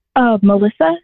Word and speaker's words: Melissa: Melissa, M-E-L-I-S-S-A.